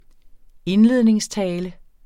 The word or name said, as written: indledningstale